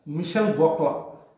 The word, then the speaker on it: Michel Gauquelin: His basic, so if you read about the scientific research that happened in astrology, there's a very big name called Michel Gauquelin, a French astrologer. And that is how you pronounce it, but the actual spelling, if you look at it, it will sound like Michel Gauquelin, okay, you can search his name.